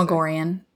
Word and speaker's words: Magorian: Majorian sounds too much like Marjorie. – Yeah. – Nah. – Or Majorie. – You gotta look Magorian. – Magorian. – Like Magrathea from it's Hitchhiker's Guide to the Galaxy. – The legendary planet of Magrathea. – Magrathea! – Oh, yeah. Nice. – Or you can go like Magorian. – Magorian. – That's like very, like, each one has, each syllable has its time to shine.